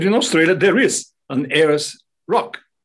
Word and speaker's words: Ayers: In Australia, we have the Ayers Rock. You say pronounce Ayers, right? Ayers Rock, that's right.